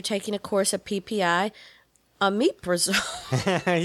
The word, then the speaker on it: Omeprazole: Omeprazole, that's what it is. Omeprazole.